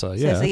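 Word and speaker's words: Cersei: With, I can never say her name, Cersa? – Cersa, yeah.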